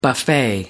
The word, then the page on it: buffet: American English